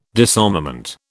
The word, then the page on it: disarmament: British English